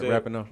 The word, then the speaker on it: Rapinoe: I said it right, Rapinoe? – Yeah, that's what I think, yeah.